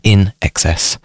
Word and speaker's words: INXS: So In Excess, it's INXS, which is supposed to be pronounced In Excess.